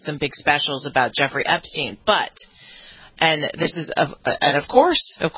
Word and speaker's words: Epstein: You have an update on Jeffrey Epstein. – Sarah, now you've got me doing it. We were talking Epstein. E-E-N. Well, as if it was spelled like that.